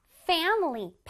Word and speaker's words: family: 여기 앞에 FAM을 아주 강하게 발음해 줄 거예요. Family. Family.